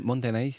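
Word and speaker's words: Montenay (もったいない): je ne sais pas si je le dis bien, hein, c'est japonais comme terme, motenei. C'est-à-dire ce terme japonais qui signifie gaspillage regrettable. C'est ça la signification ? – Exactement. Montenay, c'est ça.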